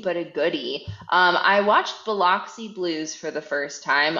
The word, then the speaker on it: Biloxi: It's Biloxi. – Yes. – Biloxi Blues is what I watched, and I liked it.